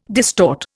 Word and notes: distort: Indian

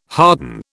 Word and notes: harden: British English

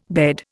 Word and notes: bed: Indian

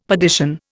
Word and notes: perdition: Indian

